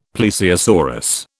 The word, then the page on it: plesiosaurus: British English